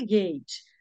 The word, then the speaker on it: gate: Se escreve GATE, mas como é que se lê gate? GUEIT, gate. Né?